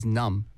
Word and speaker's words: numb: numb. – 맞아요. – N-U-M-B. – 맞아요. 네. N-U-M-B. 음. Numb. 네. 하지만 우리 그 B 발음은 네. 안 나와요. – 그렇죠. – 네. 그냥 numb.